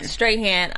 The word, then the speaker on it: Strahan: I mean, they made that comparison between, do you want to be Michael Strahan, or I'm pronouncing it wrong, Strahan.